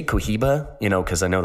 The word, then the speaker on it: Cohiba: So that is pronounced, or it's spelled C-O-H-I-B-A, but pronounced Cohiba.